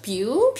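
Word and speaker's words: Pugh: Em, los protagonistas iban a ser por los medios gringos he cachado que el apellido de Flores se pronuncia Pew. Pero yo elijo les decir Puj Como un perro.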